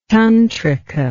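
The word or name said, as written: tantrum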